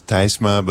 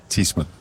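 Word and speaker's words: Tysma: It's T-Y-S-M-A. So you would pronounce it in Dutch, you would pronounce Thysma, but in English it's usually Thysma.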